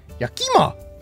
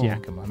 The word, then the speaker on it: Yakima: A lot of people pronounce it Yakima. Yeah. That's not how you pronounce it. It's Yakima, not Yakima.